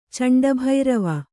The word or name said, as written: ಚಂಡಭೈರವ